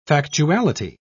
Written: factuality

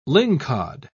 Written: lingcod

